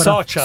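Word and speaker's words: Soča: Legga bene, c'è la C con quella, con quella roba sopra. – Socia, Socia. Allora.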